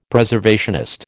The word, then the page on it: Preservationist: American English